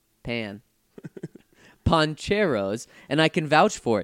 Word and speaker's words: Pancheros: He says, also, I know you guys have been getting a lot of recommendations about poncheros. Apparently, I've been saying it wrong. Surprise. – I've been saying pancheros, too. – I mean, come on. How else are you supposed to say it? It's P-A-N. – Well, P-A-N in Spanish is pan.